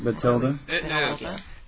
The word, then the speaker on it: Bathilda: And this week we are on Chapter 17, Bathilda's Secret. Oh, man. Bathilda? Is that how you say her name? – I always say Bathilda. – Bathilda. – Bathilda. – It is.